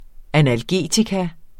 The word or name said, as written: analgetikum